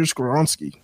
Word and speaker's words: Skoronski: he said at six, if all the linemen were off the board, he wouldn't mind going tackle and drafting Peter Skoronski.